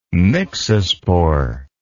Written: myxospore

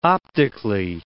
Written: optically

